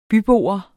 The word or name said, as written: bybo